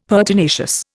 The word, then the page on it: pertinacious: Indian